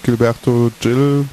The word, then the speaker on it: Gilberto Gil: Danach kommt Gilberto Gil, ich habe richtig ausgesprochen, mit Oslodum.